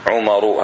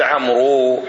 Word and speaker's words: عمرو: الواو لا تلفظ، فقط للتفريق بينها وبين عمر، حتى يقولون، حتى تحفظها، يقول استعارها عمرو من داود.